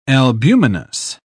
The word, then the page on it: albuminous: British English